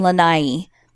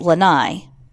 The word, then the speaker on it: Lanai: I live in Hawaii on an island called Lanai, more commonly mispronounced as Lanai.